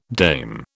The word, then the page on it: dame: British English